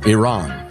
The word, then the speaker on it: Iran: So the entire world, including Iran, pronounces it Iran. Americans in the 1970s got in the habit of pronouncing it Iran in the same way that at first we said Vietnam and that sort of thing.